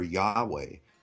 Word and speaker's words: Yahweh: In page 885, they said this Hebrew scholars generally favor Yahweh as the most likely pronunciation.